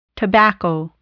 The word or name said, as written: tobacco